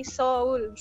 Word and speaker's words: Seoul: Cuman bacanya bukan Seoul tapi Seoul.